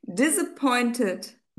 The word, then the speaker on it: disappointed: Disappointed. – Careful, because it's not a z, it's a s. Disappointed. Disappointed. – Disappointed. – And now don't pronounce, don't exaggerate it as much. Disappointed.